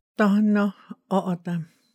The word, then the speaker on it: Tohono O'odham: Donna Otam. – Can you break that down? – Like sort of syllables, I guess. To ho no. To ho no. To ho no. O o tam.